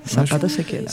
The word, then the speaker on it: Zapata Se Queda: Eles pronunciam o Z como S? – Sim, Zapata Se Queda.